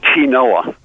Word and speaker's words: quinoa: Well, it's quinoa is how you spell it. Okay. Q-U-I-N-E-A. But it's called quinoa.